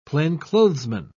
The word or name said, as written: plainclothesman